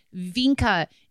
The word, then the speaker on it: Wienke: It's actually Vinka is how it's pronounced. Okay. But his name specifically is spelled last name W-I-E-N-K-E, which is kind of like wine-ke? – Wine-ke.